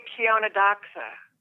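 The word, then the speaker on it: Chionodoxa: Shindoxia? Shindoxia. – Oh, yeah, that's what I meant. – How do you say that? How do you say that? – I say Kiona Doxa. – Kiona Doxa. Okay.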